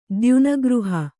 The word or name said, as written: ದ್ಯುನಗೃಹ